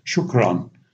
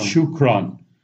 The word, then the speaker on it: shukran: And I said, well, here in Egypt, it's very simple. They just say shukran, just 2 syllables, shukran, shukran.